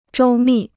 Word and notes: 周密: zhōu mì

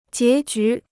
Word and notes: 结局: jié jú

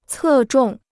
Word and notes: 侧重: cè zhòng

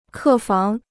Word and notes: 客房: kè fáng

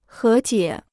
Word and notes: 和解: hé jiě

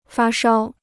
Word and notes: 发烧: fā shāo

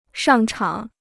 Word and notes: 上场: shàng chǎng

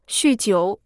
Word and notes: 酗酒: xù jiǔ

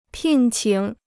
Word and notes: 聘请: pìn qǐng

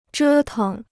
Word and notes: 折腾: zhē teng

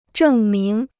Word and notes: 证明: zhèng míng

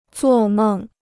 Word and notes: 做梦: zuò mèng

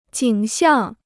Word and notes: 景象: jǐng xiàng

